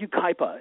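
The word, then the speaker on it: Yucaipa: It's called Ucaipa, and it means Valley of the Flower.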